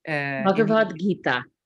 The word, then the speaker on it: Bhagavad Gita: Bhagavad Gita.